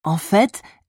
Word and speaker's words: en fait: En fait, j'ai arrêté de fumer il y a 2 mois. Bon à savoir. As with au fait, you also pronounce the T in en fait.